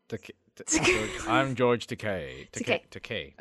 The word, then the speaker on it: Takei: Also, George Takei said in his... Did I say that right? – No, Takei.